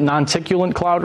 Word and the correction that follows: noctilucent: right? – Noctilucent. – Non-ticulant cloud? Noctilucent. Yeah. I've mispronounced it like 1,000 times.